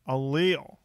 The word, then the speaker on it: Allele: Allele.